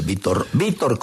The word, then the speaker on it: Vitor: Ve, ahora que decís Vitor Roque, Vitor, como dicen ellos, Vitor Roque, así se escribe.